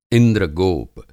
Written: इंद्रगोप

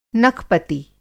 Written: नखपति

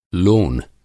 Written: लोन

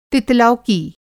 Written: तितलौकी

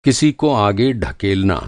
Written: धकेलना

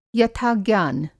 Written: यथा-ज्ञान

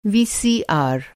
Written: वीसीआर

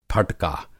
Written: फटका

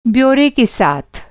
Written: ब्योरा

